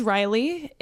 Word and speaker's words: Ryli: But hey, guys, my name is Ryli. It is spelled R-Y-L-I. And all my social media is I am Ryli. So I-A-M-R-Y-L-I, and you can find me on all those things.